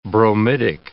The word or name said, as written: bromidic